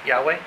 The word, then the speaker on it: Yahweh: As a matter of fact, he knows many rabbis, and he said they have proof that the name is pronounced Yahweh.